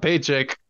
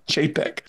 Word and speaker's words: Chapek: You almost said Bob Paycheck. – I have to intentionally say his name right now because I'm so used to saying Bob Paycheck. It's Bob Chapek.